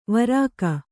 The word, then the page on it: ವಾರಕ: varaka